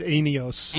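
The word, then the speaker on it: enios (αἰώνιος): He's a noted Greek scholar, and he put it this way, that the word for eternal is, how is that pronounced? Is it enios? – I've always pronounced it enios. – Enios, okay. – Or right. – eon, the shorter version is eon.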